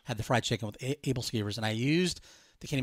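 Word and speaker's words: æbleskiver: I think I called it like Abel skivers or something. And my wife says, "No, those are Abel skivers."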